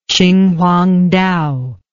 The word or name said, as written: qinhuangdao